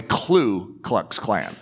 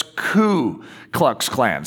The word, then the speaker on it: Ku Klux Klan: And the explosion of the Ku Klux Klan. By the way, one of the hardest names to speak is Ku Klux Klan. I always want to say Klu Klux Klan, but it's Ku Klux Klan.